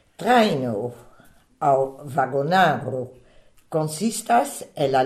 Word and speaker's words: trajno: Traino, ça s'écrit T-R-A-J-N-O. Le J, je le rappelle, c'est un I bref, donc la première syllabe, trai, et la deuxième, no. Traino.